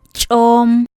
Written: ch'oom

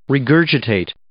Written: regurgitate

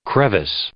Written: crevice

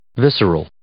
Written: visceral

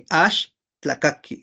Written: axtlakaki

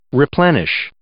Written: replenish

